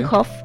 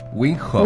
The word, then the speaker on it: Wim Hof: Pues estamos hablando acerca del método de Wim Hof. ¿Lo dije bien? Wim Hof. – Wim Hof.